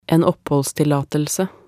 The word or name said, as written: en oppholdstillatelse